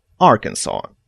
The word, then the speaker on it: Arkansas: Но в 1881 году было официально утверждено произношение Arkansas, И человек, который стоял за французский вариант, победил.